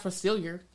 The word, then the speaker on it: Facilier: This is pronounced Facilier.